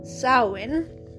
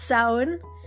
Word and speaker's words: Samhain: Today is, I'm going to try to say this correctly, Samhain. I have to think of cow first to say it right, because Irish spelling is such. But yeah, it's Samhain, and it is, I think, I read an article on it today.